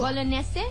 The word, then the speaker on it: Bolognese: Isang baloney. – Baloney. – No. – Okay. – But how do you say it in Italian? – Well, Bolognese.